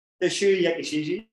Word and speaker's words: Yakushiji: Yakushiji. Hope you've pronounced that right. – That was okay. – I've been nervous if I pronounced that all day. – Yakushiji, you get that right.